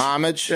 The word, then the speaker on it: homage: Did I say homage or homage? – I think it's an homage. – Homage. – Homage. – Yeah. – It's Amish. – It's a silent H, I believe.